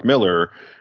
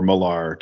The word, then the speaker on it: Millar: I learned it from Mark Miller, or Millar, depending on your pronunciation,